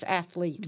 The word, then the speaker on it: athlete: And I know you don't do this, but it's very common athlete. – Athlete. – Mm-hmm. Mm-hmm. It's athlete.